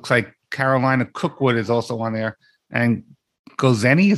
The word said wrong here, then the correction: Gozney: is that how pronounced that right? It's pronounced Gozney.